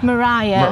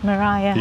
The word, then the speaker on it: Maria: Maria, is it Maria? – Mariah. – Mariah, I mean, you spell it Maria, but they back then they said Mariah.